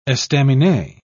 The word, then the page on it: estaminet: British English